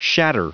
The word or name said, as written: shatter